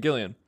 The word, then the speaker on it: Gillan: and Karen Gillan. – Mm-hmm. – And, or Gillian, sorry. I always say Gillian now because of Gillian Flynn. She, like the author of Gone Girl, she's ruined that pronunciation for me.